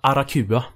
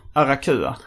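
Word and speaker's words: Aracuan: Hur uttalas det? Arakua. Arakua, okej. Okay.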